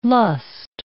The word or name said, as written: lust